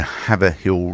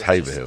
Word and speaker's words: Haverhill: Great Yarmouth go to Haverhill Rovers today. Right, so... – we're pronounced at Haverhill. – Haverhill, sorry. – Yeah, oh, sorry, I'm being told it's Haverhill.